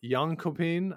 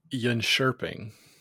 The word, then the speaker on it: Jönköping: The World Junior Curling Championships took place over in Jönköping, I believe, back in Sweden. Is that right? – It's pronounced Jönköping. – Oh, excuse me.